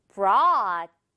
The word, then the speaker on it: brought: 자, 마지막 단어는 앞에 이제 br로만 바뀌고 발음 계속 똑같아요. 가져왔다. 해볼까요? Brought.